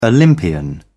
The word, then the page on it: olympian: British English